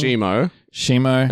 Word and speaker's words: Shimo: Well, we've got Shimo. I believe that's how it's pronounced. And we've got Scar King. – Shimo.